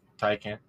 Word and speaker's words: Taycan: Taycan.